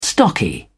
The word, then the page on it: stocky: British English